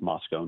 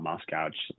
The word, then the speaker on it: Moscow: The Corner Club is a famous bar in Moscow, Idaho, and it's pronounced Moscow, not Moscow, just for the uninitiated.